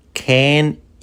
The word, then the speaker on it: can: Can you bring it tomorrow? Verbo poder can. Pronunciado can con E.